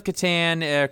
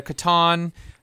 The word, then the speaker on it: Catan: Settlers of Catan, Catan. Depending on what way you pronounce it, it's kind of like Monopoly, I guess, except for it's resource-based instead of money-based.